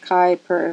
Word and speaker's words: Kuiper: Kuiper is pronounced Kai per rhyming with Viper.